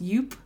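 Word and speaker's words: Joop!: And this is, I think it's pronounced Yoop. – That's how I've always heard it said.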